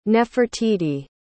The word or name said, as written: nefertiti